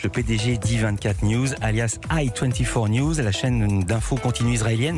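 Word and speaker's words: i24NEWS: On va commencer par la présentation de I24 News, j'ai dit alias I24 News. Vous prononcez à l'anglaise, même sur les bandes annonces en français, la preuve.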